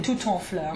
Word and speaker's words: tout en fleur: tout en fleur, right? – Mm. Oui, oui. – Tout is a single syllable that always takes liaison.